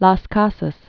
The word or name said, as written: Bartolome de Las Casas